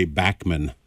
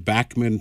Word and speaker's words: Bachman: When I was in Canada, and when they pronounce Bachman Turner Overdrive, they say Bachman. At least the radio station I was listening to, they pronounced it Bachman Turner Overdrive.